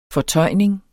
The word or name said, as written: fortøjning